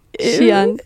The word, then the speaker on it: Xi'an: Wart auf dem Campus und habt dann danach am Samstag einen Ausflug gemacht zu einem Ort, den schreibt man X-I-A-N. Ihr wisst natürlich viel besser, wie man den ausspricht. – Xi'an. – Xi'an. Genau.